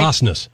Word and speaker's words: Osnes: Osnes.